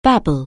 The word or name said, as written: babble